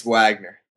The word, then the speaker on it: Wagner: It's not like Wagner or some other pronunciation. It's Wagner, right? – Siri would disagree with me, but it's Wagner.